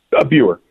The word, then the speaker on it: Buer: Buer.